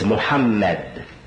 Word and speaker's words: Muhammad: But the name of our Prophet is the same, and is to be pronounced the same, regardless to how the spelling varies. It's Muhammad, Muhammad, Muhammad, Muhammad.